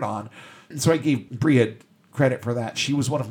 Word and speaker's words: Brigid: And then there was Briad, or if you want to pronounce it the more American way, Brigid,